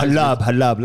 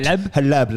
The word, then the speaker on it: Halab: So yeah, halab. Like halal, but halab. Like Habibi.